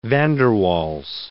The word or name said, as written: Waals, van der